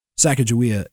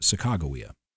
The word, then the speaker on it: Sacagawea: Sacagawea is how I learned to pronounce it in school. It's more accurately pronounced Sacagawea.